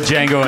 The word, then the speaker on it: Django: The Django and Jane. – You have to say the D is silent.